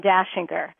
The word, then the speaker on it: Dachinger: Dashinger. So you understand, my name is not spelled like the Christmas song. It's Austrian, D-A-C-H-I-N-G-E-R.